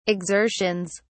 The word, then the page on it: exertions: British English